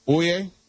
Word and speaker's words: Oia: And let me tell you, if you go to Santorini, my suggestion would be to stay in the Oie, which is spelled O-I-A.